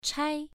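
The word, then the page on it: chai: tone 1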